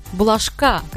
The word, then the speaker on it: Булашка: «Булашка», как мне указали, что ударение на последний слог, в исполнении Марьям Казиевой.